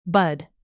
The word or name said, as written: bud